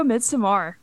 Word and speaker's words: Midsommar: I like how you pronounce Midsommar too. It's very nice. – Thank you. – Yeah. Apparently that's the right way.